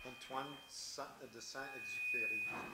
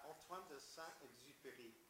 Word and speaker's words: Antoine de Saint-Exupéry: Antoine de Saint-Exupéry. – Say it louder. Did you see? – Antoine de Saint-Exupéry.